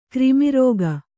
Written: ಕ್ರಿಮಿರಾಗ

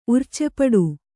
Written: ಉರ್ಚೆಪಡು

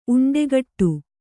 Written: ಉಂಡೆಗಟ್ಟು

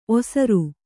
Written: ಓಸರು

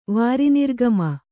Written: ವಾರಿನಿರ್ಗಮ

